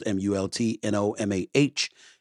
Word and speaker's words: Multnomah: I believe that's how it's pronounced M U L T N O M A H. If I mispronounced it, my apologies, but it sounds like Multnomah